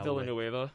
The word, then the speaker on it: Villanueva: Even like Alejandro, I can't pronounce his last name, who's now Villanueva.